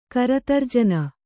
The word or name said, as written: ಕರತರ್ಜನ